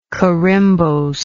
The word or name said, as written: corymbosely